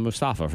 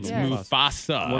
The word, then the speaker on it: Mufasa: It's Mustafa from The Lion King. – Yeah. It's yes. Mufasa.